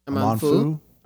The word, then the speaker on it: Amanfu: Say A-M-A-N-F-U. – F-U. – Yeah. – Amanfu? – Amanfu.